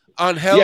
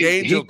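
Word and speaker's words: Ángel: So it looks like Angel, but if you pronounce it like the way it is in its original, I guess it's On Hell. I don't know. – On Hell. Hell being the dominant part of that. On Hell.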